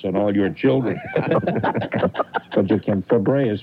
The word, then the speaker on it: Fabares: And she pronounces it Fabre, Shelley Fabre.